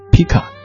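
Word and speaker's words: Pchy: 刚才放的是电影《暹罗之恋》男主角之一的这个 Miu，他这个他的本名哈该念作 Pika，他演唱的一首《只有你》。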